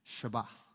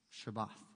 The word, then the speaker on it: shabach: This word here is spelled S-H-A-B-A-C-H, Shabbat, Shabbat.